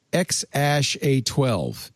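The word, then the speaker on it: X Æ A-12: Musk says the name's pretty much pronounced X-Ash-A-12. A-E, some speculated, was actually Ash. It's a leaded A and E, some languages have, and it's often pronounced Ash.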